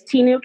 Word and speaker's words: Tinu: My first name's Tinu, capital T as in Tom, I, N as in Nancy, you.